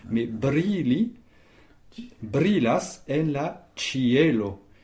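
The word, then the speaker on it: ĉielo: Cielo, ça s'écrit C chapeau I E L O. Et où est l'accent tonique? – Sur le E. – Sur le E. – Voilà, sur le E qui se prononce E, effectivement. Cielo. Cielo. Cielo. Il y a Cielo. Trois syllabes au sens des espérantistes. Oh. Et c'est simplement la lettre E que l'on accentue.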